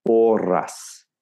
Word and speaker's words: porras: which is spelled P-O-R-R-A-S, porras, porras. Right? Borras, right? It's a thicker version of the churros. So I walked in there and I said I wanted some porras, right? So I've introduced a schwa, right? So it almost sounds like the word porous in English, you know, the stone is porous or something. I've gone rus, I've gone porras.